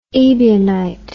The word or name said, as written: Ebionite